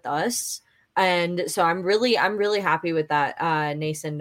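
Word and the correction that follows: Noesen: Yeah, Noason.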